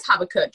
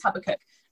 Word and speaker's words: Habakkuk: So from listening to, of course, church on Sundays, and really just hearing the story of Habakkuk. – Let's right? – go with that. – Am I saying it right? – It's around about that. – It is Habakkuk. Yeah, it is Habakkuk.